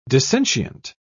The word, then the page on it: dissentient: British English